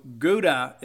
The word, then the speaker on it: Gouda: And so Gouda is how they pronounce it. It is of German descent or German origin.